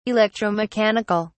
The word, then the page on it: electromechanic(al): British English